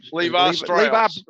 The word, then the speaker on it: Levi Strauss: How do you say it? – Levi Strauss.